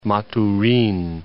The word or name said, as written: Maturin